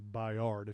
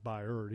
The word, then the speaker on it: Byard: It's actually Byard if you actually just looked at it and pronounced it, but it's Byard.